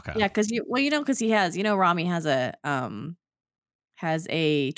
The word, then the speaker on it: Rami: I want to see Rami. Is that how you say it? I've been saying Rami. Rami Malek? – Rami.